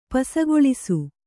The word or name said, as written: ಪಸಗೊಳಿಸು